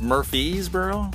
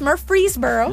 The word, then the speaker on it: Murfreesboro: We are in Murfreesboro, Tennessee, and it's spelled Murfreesboro, but yes, is it pronounced Murfreesboro? – No, it's Murfreesboro.